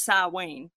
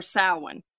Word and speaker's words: Samhain: It says in Celtic traditions, Halloween, and it's spelled Samhain, but it's pronounced Samhain or Samhain, however you want to call it, was a day when the line between the living and the deceased was blurred.